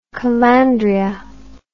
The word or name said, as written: calandria